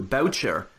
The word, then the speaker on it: Boucher: But uh, yeah, turns out Boucher, he always hated people pronouncing his name that way too. Boucher is how he wanted his name pronounced.